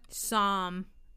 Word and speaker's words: Psalm: Psalm. – Thank you. Say it again. – Psalm. – 46, 1 through 3. Guys, I'm not a religious person. I don't know how to say these things. – Yeah. The P is silent.